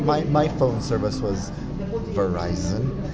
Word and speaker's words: Verizon: It's Verizon. – Well, I know. I always say Verizon. Verizon. Oh. – Like, but it rhymes with Horizon.